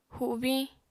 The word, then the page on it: hubiñ: apatani